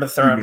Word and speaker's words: Mathurin: I actually don't know how to say his last name. Is that right? Mathurin. – I've heard Mathurin most broadcasts.